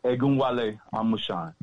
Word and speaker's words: Egunwale Amusan: Egunwale Amusan.